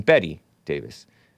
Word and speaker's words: Bette: Oh, Davis pronounced her name Betty Davis.